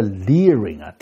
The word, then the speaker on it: leering: 这个 learing 的确是个错字，但它的读音呢跟另一个单词 L-E-E-R-I-N-G Learing 是一模一样的。